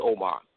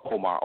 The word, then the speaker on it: Omar: First of all, my name is Shahid Omar, and that's spelled S-H-A-H-E-E-D. My last name is Omar, O-M-A-R. My address is 927 Wasena, W-A-S-E-N-A, Avenue Southwest, Suite 2.